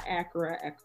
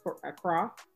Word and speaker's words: Accra: I got a friend that's moving to, I always say it wrong, not Accra, Accra.